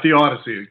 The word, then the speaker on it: Theodicy: Is that the right pronunciation? Theodicy? – Yeah, Theodicy, correct.